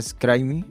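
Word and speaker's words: Scribeme: Pronuncia Scrimey.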